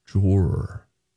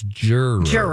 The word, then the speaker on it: juror: Drawer. It's juror. – Juror. Mike. – Google. – One second, please. – Juror. – I bet they're going to go with an ER sound at the end.